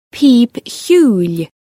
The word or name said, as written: pìob-chiùil